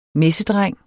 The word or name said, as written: MESSEDRENG